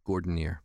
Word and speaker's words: Gordinier: Just think of Jeff Gordon, the race car driver, plus an ear. Gordonier.